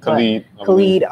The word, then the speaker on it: Khalid: Khalid.